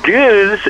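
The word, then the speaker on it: gueuze: Yeah, so goose is, I'm very, very impressed with your pronunciation. that's a hard one to get down.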